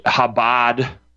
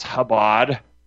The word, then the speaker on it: Chabad: Chabad Lubavitch, right? Had a Jew call in and correct me, calling it Chabad. It's Chabad problem. Call it what it is. It's pronounced Chabad.